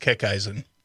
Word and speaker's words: Kickeisen: It's pronounced Kickeisen. – Kickeisen. – Yep. – Kickeisen. – Yeah. – Yeah. Slightly German.